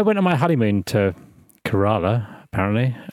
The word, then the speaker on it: Kerala: I googled how to say it, and a YouTube video told me it's Kerala.